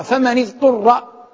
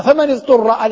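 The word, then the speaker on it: فمنِ اضطر: من هذه شرطية، من هذه شرطية، والأصل أنها مبنية على السكون من، لكننا نقرأها فمن اضطر بالكسر لالتقاء الساكنين، لأن العرب ليس في كلامها توالي ساكنين، ليس في كلامها توالي ساكنين. فإذا قلنا فمن اضطر، الهمزة التي بعد النون همزة وصل لا تنطق أصلا، فنأتي للحرف الضاد وهو ساكن اضطر في الأصل، فلما كان ساكنا في الأصل التقاء الساكنين يفرض علينا أن نكسر أحدهما، لأن الكسر أقرب إلى السكون حتى لا يكون هناك خلاف في نطق اللغة، لأن العرب كما قلت لا توالي بين ساكنين.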